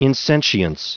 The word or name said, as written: insentience